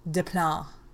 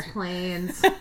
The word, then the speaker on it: Des Plaines: By the way, if you're not from the Chicagoland area, I did not mispronounce Des Plaines. It is actually called Des Plaines here. – Des Plaines. – If you looked it up, it would look like en français, Des Plaines.